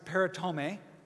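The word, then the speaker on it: peritome (περιτομή): The word for circumcise means peritome. That's how you say it in Greek.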